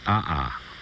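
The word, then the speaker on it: aa: It's a Hawaiian word, and it's a kind of lava, A-A, pronounced ah-ah, with the accent on the first ah.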